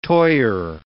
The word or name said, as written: toyer